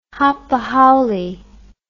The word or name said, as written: hapahaole